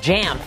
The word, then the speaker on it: JAMP: I mispronounced that before. It's just JAMP. It's not even, you're not pronouncing each individual letter. It sounds like, I don't know, like you're grunting.